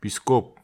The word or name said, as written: piskop